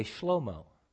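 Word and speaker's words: Shlomo (שלמה): In Hebrew, you would actually not say Solomon, you would say Shlomo. That's how you pronounce his name.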